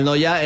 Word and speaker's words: Neuer: Manuel Noya era mi abuelo. Yo digo, se pronuncia, es que es Neuer. Pero se pronuncia casi igual, sí, sí. – Sí, sí, se pronuncia en Noya.